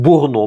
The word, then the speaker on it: Бурно: Далее, значит, соответственно, и первая докторская по арт-терапии, то есть когда человек больной, какие-то душевные недуги, была сделана Марком Евгеньевичем Бурно, в данном случае точно ударение на последнюю букву.